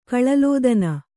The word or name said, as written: ಕಳಲೋದನ